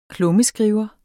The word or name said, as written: klummeskriver